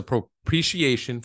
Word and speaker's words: propitiation: propitiation for our sins.